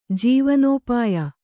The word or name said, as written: ಜೀವನೋಪಾಯ